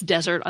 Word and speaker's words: desert: The arid place, desert, on the other hand, has one S and the first syllable is stressed. The noun that means what you deserve, spelled D-E-S-E-R-T, with one S, confusingly has the second syllable stressed, just like the word that refers to cake or cookies.